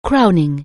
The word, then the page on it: crowning: anglais